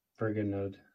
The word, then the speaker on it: Vergenoegd: Vergono. – Try it again. – Vergono. – Third time's a charm. – How would you say that? – Vergonode. – Okay.